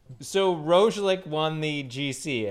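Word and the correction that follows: Roglič: Roglic? – Yeah, okay.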